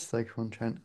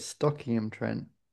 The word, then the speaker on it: Stoke-on-Trent: It is Stoke-on-Trent. I know exactly how you say it wrong. Do you want me to tell you how you say it wrong? – Yeah. – You call it Stockingham-Trent. – That's it. – Yeah, which is obviously wrong.